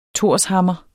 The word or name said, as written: torshammer